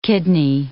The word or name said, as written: kidney